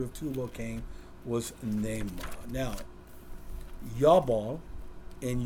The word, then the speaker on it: Jabal: That's how you pronounce it. Not, I always said Jabal, but Yabal.